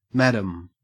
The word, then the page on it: madam: Australian English